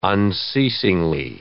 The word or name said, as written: unceasingly